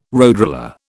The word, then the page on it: roadroller: British English